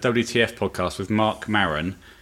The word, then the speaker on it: Maron: And we're going to stick with that pronunciation, aren't we? Maron. – I don't intend to say his name at all. – Okay. From now on, we might call him Mark or double M. – Yeah. – If we're pronouncing it wrong, please don't let us know. – I don't think we are. I think that's fine.